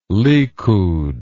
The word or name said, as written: likud